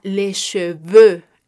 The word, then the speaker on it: cheveux: Este sonido lo podremos encontrar en palabras como los cabellos, los cabellos será les cheveux les cheveux. En francés se escribe C-H-E-V-E-U-X.